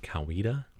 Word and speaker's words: Coweta: So just because I've lived down there, I know that they pronounce it Coweta.